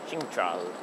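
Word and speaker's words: Centrale: But actually, don't pronounce it on discovering Central. It's something like Cintral. Cintral, actually.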